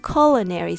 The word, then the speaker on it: culinary: This word can be a bit tricky because it looks like it should be pronounced culinary. But in English, we often surprise people. Here is the phonetic breakdown. Culinary. Let's go through it slowly. Cul. The first syllable is stressed, and the here is a short U sound, as in cup.